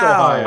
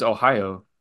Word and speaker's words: Ohio: Fuck Ohio. I think the Japanese pronunciation is Ohio. – Yeah. – Not Ohio.